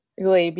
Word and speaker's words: Glebe: There's a plan to bulldoze 112 public housing dwellings in, I think you pronounce this Glebe? – Glebe, yes.